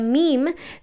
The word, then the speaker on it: meme: En, inglés, eh, meme se pronuncia meme, entonces eso es una palabra de una sola sílaba. Él quería buscar un monosílabo que, se, que sonara, que rimara como la palabra jean en, inglés, es decir, la palabra gen, ¿no?